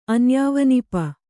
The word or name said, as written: ಅನ್ಯಾವನಿಪ